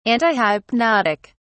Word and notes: antihypnotic: British English

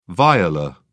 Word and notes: viola: British English

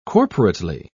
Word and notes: corporately: British English

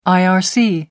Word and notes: irc: American English

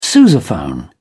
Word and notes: sousaphone: British English

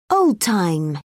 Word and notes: old time: British English

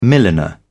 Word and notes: milliner: British English

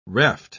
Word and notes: reft: British English

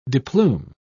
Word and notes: deplume: British English

